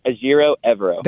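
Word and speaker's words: Ejiro Evero: That's how do you pronounce his name? The DC for the Carolina Panthers. – Ajiro Evero. – That's it. I want to make sure I got it right. Ajiro Evero.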